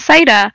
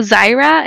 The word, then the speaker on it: Zaira: And I'm like, my name is Zaira. And they're like, how do you spell that? And I'm like, Z-A-I-R-A. And they'd be like, oh, Zaira? And I'm like, yeah, Zaira.